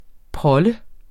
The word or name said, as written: prolle